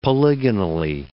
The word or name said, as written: polygonally